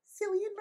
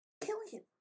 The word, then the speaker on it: Cillian: It's also not Cillian Murphy. Killian.